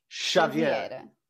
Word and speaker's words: Xavier: It's it's the sh sound. Xavier.